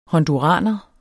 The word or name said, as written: honduraner